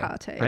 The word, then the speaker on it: Hecate: Hecate. – Hecate. – I just hope that we have Hecate. Hecate. – It's like Tecate.